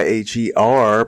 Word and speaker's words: H.E.R.: My goodness, that is a lovely song by H.E.R., pronounced her.